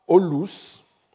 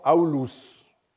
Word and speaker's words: Aulus: Olus était une forme, une prononciation vulgaire du prénom aulus parce que le hao, à partir d'un certain moment, au début de notre ère, était prononcé dans les quartiers populaires Olus.